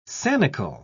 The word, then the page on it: sanicle: British English